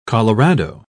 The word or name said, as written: colorado